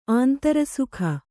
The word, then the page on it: ಆಂತರಸುಖ: kannada